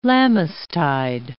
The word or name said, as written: Lammastide